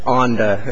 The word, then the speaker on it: ONDA: Yes, I should explain that ONDA is how I pronounce the acronym for the appellant group.